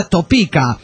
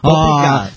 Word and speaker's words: Topeka: Y también solo corregir al pirata, es la ciudad de Topeka, así se escribe Topeka, pero se escribe así, pero se pronuncia Topeka. – Topeka.